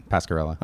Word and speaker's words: Pascarella: Pascarella. – Okay, cool. I was like, is that a soft double L? Am I saying that right?